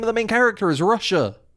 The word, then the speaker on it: Rusher: Russia, but it's spelled like R-U-S-H-E-R.